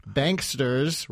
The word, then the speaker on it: Banksters: So in that context, it's surprising that you would write a book called Greedy Bastards, How We Can Stop Corporate Communists, Banksters, rhymes with gangsters, and Other Vampires from Sucking America Dry.